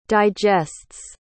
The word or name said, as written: digests